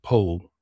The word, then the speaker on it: Powell: Well, it's spelled Powell, but uh, with a certain affectation, is known as Anthony Pole.